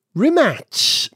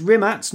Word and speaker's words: Rimac: Well, it's like Robert Kubica's name. – Yeah. – The C in Kubica, as he's sometimes pronounced, it's a T sound. – Yeah. Yeah, so it's Rimac, not Rimac.